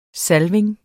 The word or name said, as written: salvning